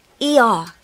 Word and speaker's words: Iyar: ER, which is spelt I Y A R, month of a spring board.